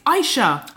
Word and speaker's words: Aicha: Aicha. – Aicha. – Like a silent A.